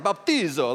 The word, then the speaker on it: baptizo: So it's Greek, so you're gonna kind of like baptizo. Like I want you to say it like that, okay?